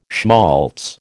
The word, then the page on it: schmaltz: American English